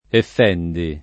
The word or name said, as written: effendi